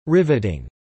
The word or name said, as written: riveting